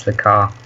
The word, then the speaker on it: Lacarre: Exactly, Charles Lacarre.